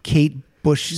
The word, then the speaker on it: Kate Bush: And that's a Kate Bush song? – Yes.